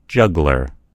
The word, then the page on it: juggler: American English